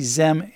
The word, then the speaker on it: xem: And if you're looking at the pronunciation, it would be Z, Zem, and Zers.